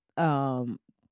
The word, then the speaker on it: Pyeongchang: It's Pyeongchang, but I feel like that P is silent, maybe. Let's see if I can get Google to tell me how to say it. Pyeongchang. Yeah. So the P wasn't silent.